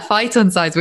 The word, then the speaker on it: phytoncides: Yeah, phytoncides, which are these volatile organic compounds which are released by trees.